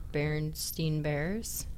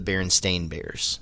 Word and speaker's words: Berenstain: Well, it turns like out too. That they've been the Berenstain Bears what? – The whole time. – They're you were not, saying it wrong? – Yes, look.